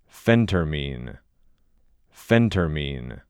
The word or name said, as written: Phentermine